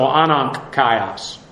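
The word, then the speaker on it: anankaios (ἀναγκαῖος): Well, the first word he uses here is actually the nominative neuter singular of anankaios, A-N-A-G-K-A-I-O-S. And again, because of phonetics, the GK, the two rough consonants, you pronounce it like an N. So, anankaios. Eight times it's used in the neuter to denote something or whatever might be necessary.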